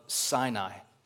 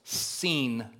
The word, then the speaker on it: Sin: Well, in some ways that's true, but Sin is simply short for the region of Sinai. And actually in Hebrew, it's pronounced seen, not sin.